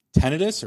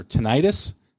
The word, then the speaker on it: tinnitus: and I do have a little bit of tinnitus or tinnitus, depending on who you talk to is how they pronounce it,